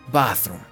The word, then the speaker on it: bathroom: Entonces, Aquí pendientes pilas porque recuerden que cada palabra que tenga una T y una H, la T y la H se pronuncia como si pusiéramos la lengua contra los dientes de arriba y sopláramos. Bath, bath, bathroom, baño, bathroom.